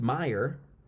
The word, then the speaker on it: Mayer: So this is a name that's Mayer. It's actually pronounced Meyer.